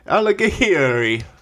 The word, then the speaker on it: Alighieri: It's Italian. And I think it's pronounced Alighieri.